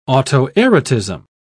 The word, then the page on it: autoerotism: British English